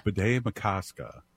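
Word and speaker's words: Bde Maka Ska: Bede Makaska.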